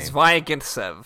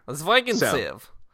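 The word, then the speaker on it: Zvyagintsev: Zvigintsev. – Yeah. – Zvigintsev.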